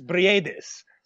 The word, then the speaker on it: Bredis: It's Maris Bredis. So you got to pronounce both the I and the E in the last, Bredis, basically.